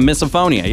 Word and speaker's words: misophonia: Yeah, misophonia, yeah.